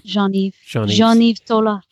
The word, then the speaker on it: Jean-Yves Tola: And how do you say John's name? – Jean-Yves Tola.